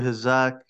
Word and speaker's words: Hazok: Yeah, man, it's your boy Hazok, you know, coming out of Rochester, New York, currently in uh, Malaysia.